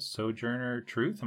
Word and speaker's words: Sojourner Truth: I'm excited to learn about Sojourner Truth. Am I saying that right? – Yes, very nicely said.